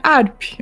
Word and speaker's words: HAARP: Que é, me corrija se eu estiver falando errado, H-A-A-R-P. ARP. Ou HARP. É isso mesmo? É, ARP. Eu falo ARP mesmo, é isso.